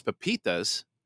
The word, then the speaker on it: pepitas: And so pumpkin seeds, which are also known as pepitas, that's how you pronounce it.